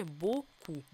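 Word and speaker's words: beaucoup: «Ö», «а», «ü» мы читаем как «о», «о», «ü» мы читаем как «у». Помним, что это закрытый «о», поэтому глубокий звук. «У» тоже, чтобы не улетело ни в какое «ü». «П» на конце не произносим, и буква «с» перед «о» тоже читается у нас как «к», а не «с». Ну и получили «боку».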